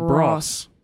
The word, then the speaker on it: Bros: And we mentioned it, and Malcolm replied back and said, to help you further, bros rhymes with Ross from Friends. – So it's, so it's apparently bros.